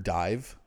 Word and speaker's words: DIIV: But yeah, I think I Can Fool Anybody in This Town reminds me a lot of, like, have you heard Dive? – No. – They spell it weird. It's like D-I-I-V.